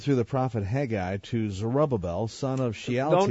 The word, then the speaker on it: Zerubbabel: We'll save Zerubbabel for the end.